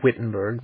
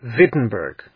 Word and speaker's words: Wittenberg: They pronounced their W's as V's, so they wouldn't have said Wittenberg, they would have said Wittenberg.